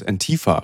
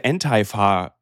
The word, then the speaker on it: Antifa: Antifa. So, sie sprechen das so deutsch aus. Also eigentlich müsste es ja Antifa ausgesprochen werden, aber egal.